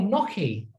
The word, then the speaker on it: gnocchi: And she was like, oh, gnocchi. I was thinking, why the fuck would you put a G in front of it if it's not pronounced like that? – Fuck it, them Italians for you, didn't it?